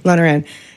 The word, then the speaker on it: Lonergan: Lonergan.